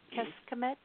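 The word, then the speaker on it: Kecskemét: I'm in it's spelled K-E-C-S-K-E-M-E-T. That's the name of the city. – K-E-C-S. – K-E-M-E-T. – Keskemet? – Yes.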